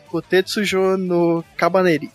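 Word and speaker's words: Kotetsujou no Kabaneri (甲鉄城のカバネリ): Ou Kotetsujou no Kabaneri? Nome difícil de pronunciar. – Deixa eu tentar. Kotetsujou no Kabaneri.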